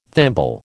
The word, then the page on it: thimble: American English